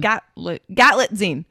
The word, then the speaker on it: Galitzine: Yeah, Rhymes with Magazine. That's him. – Galitzine.